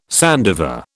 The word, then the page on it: sandiver: British English